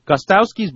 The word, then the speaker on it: Gostkowski: I really have to work on that because I still find myself saying Gostkowski. – I say it wrong every time. – Gostkowski. Gostkowski's blocked kick was not entirely his fault.